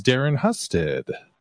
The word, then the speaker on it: Darren Husted: And my guest today is Darren Husted.